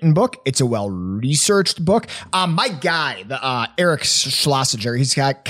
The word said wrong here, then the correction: Schlosser: kind of I should probably say his name correctly. Eric Schlosser has kind of an interesting background and upbringing.